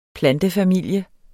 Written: plantefamilie

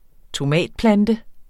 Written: tomatplante

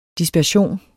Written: DISPERSION

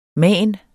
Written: manen

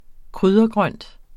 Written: kryddergrønt

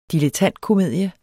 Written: DILETTANTKOMEDIE

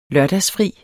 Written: lørdagsfri

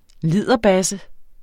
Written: liderbasse

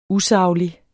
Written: usaglig

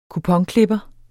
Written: kuponklipper

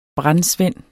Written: brandsvend

